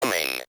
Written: coming

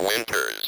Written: winters

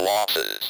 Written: losses

